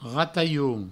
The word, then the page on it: ratalhon: rataioun